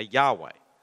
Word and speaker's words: Yahweh: Throughout the Old Testament, the one true God revealed himself to his people using this name, YHWH, which we sort of have to pronounce some way, so we say Yahweh.